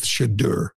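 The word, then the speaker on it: Shedeur: and he had a problem with Shadur. Am I saying that right? Shadur? – I think so.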